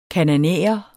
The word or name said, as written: kanaanæer